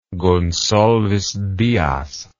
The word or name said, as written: goncalves dias